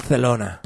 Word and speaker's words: Barcelona: Barcelona.